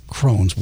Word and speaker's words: crosnes: Yeah, C R O S N E S, but pronounced Crohn's.